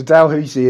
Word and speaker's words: Dalhousie: You also have another one, and forgive me for pronunciation, but Dalhousie, is that how you pronounce it? – Okay. I don't know if you should take my pronunciation as to be honest, but yeah, Dalhousie. So Dalhousie is our second project.